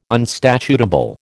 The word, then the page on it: unstatutable: American English